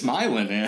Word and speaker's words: Milan: Like, well, in Michigan, you know, you might have heard of Milan in Italy. In Michigan, yeah. We have Milan. So that's just how you say it. It's Milan.